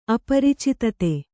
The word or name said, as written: ಅಪ್ರಚೋದಿತ